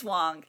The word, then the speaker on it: Wang: It's Wong. – And it's Wong. – Yeah. It's spelled W-A-N-G. Guys. It's pronounced Wong.